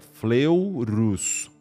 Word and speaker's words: Fleurus: A Batalha de Fleurus, ela pronuncia assim, ó, é, é Fleurus, Fleurus.